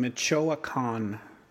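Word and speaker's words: Michoacán: Michoacan.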